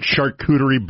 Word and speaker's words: charcuterie: What charcuterie board does that pair with? Did I say that right? – That was good.